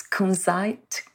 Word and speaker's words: kunzite: I think you pronounce kunzite. – That's right. – Oh, kunzite.